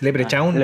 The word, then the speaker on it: Leprechaun: Eh, bueno, se pronuncia así, pero yo nunca lo he dicho así, nunca he dicho crown sino que es Leprechaun,